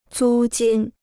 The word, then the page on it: 租金: zū jīn